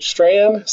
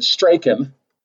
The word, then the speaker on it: Strachan: So I edited that show this morning, and they pronounced it Strand, so I'm pronouncing it Strand. I, I always said Straken.